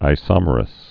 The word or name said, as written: isomerous